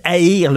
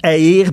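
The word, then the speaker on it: Jair: Aïr. Le gars s'appelle Aïr. Ça ne s'invente pas. J'aïr, mais ça se prononce Haïr Bolos.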